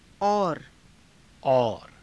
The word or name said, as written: aura